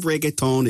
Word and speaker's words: reggaeton: We'll hear from Carlos Vives a little later, but right now, the queen of reggaeton is up next. – Yes, Bobby. Also, great pronunciation on reggaeton.